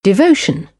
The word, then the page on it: devotion: British English; əʊ